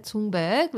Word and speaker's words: Thunberg: So I found a video that Greta Thunberg, which is how you pronounce her name, and it's always a bit tricky, created.